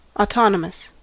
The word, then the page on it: autonomous: American English